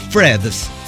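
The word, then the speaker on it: Threads: H-R-E-A-D-S. Hasta para deletrearlo es un pedo. Bueno, en realidad nos han dicho que se pronuncia Freds. Que vendría a ser como Alfredos.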